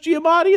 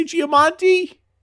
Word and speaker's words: Giamatti: You think my life's not hot enough where people confuse me with Paul Giamatti, who are idiots that can't pronounce Giamatti and they say Giamonti? – Yeah, it must be really hard.